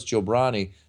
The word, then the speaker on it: Jobrani: That's the kind of person who, and they continue even when I say no, it's jabroni. They continue to mispronounce it because they.